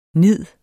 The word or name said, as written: nid